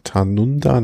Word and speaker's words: Tanunda: Und dann kommen wir zur ersten Etappe, Vanunga, wie habe ich es jetzt eben ausgesprochen? Tanunda nach Tanunda.